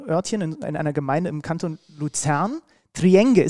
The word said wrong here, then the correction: Triengen: ist das richtig? Spricht man das richtig? – Äh, ist Triengen, das ganz falsch? Triengen, ja. aber äh, das stimmt nicht ganz.